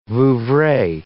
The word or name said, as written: Vouvray